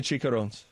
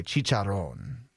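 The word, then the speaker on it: chicharrón: And I'm not eating the chicharrones. – Why? And that's not how you pronounce it, by the way. Chicharron.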